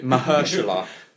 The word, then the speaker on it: Mahershala: Mahershala, that's his name.